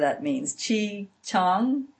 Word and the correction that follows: Qigong: I don't know how to say that. And they also, some of the ladies went to a divine straightener. Qigong is how you say it.